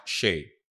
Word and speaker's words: Seyi: Shay Brown, like S-H-A. – Like Shea Butter. – Okay, it is S-E-Y-I, but it's pronounced like Shea Butter. – S-A-Y? – S-E-Y-I, but it's pronounced Shay.